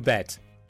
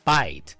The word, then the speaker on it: bet: 在今天的节目中，我要和大家分享一个特别实用、特别常见的高频表达，叫做 "You bet, you bet, you bet, you bet"。注意这里的 bet bet 不要读成了 bite，也不要读成了 bait，应该是 bet。